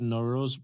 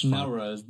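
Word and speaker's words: Nowruz: It is the first day of the spring and it is called Noroz. Wait, I thought that was actually pronounced Nowroz. – Noroz. – But you'll know better than me.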